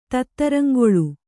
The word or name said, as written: ತತ್ತರಂಗೊಳ್